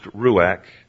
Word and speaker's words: ruach: It's pronounced ruach. If you want to spell it for anybody writing notes, it's R-U-A-C-H. And I know it's ruach because I listened to a Hebrew person pronounce it.